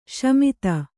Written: ಶಮಿತ

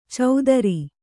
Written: ಚೌದರಿ

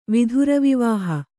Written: ವಿಧುರವಿವಾಹ

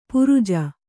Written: ಪರಿಜು

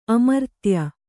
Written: ಅಮರ್ತ್ಯ

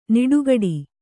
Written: ನಡುಗಟ್ಟು

